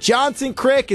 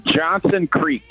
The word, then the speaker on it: Johnson Creek: Is it Johnson Crick? Is it just Crick? – It's Johnson Creek.